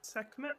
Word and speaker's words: Sekhmet: Fabian recognizes that Sekhmet. How do they pronounce this? – Sekhmet? That sounds right.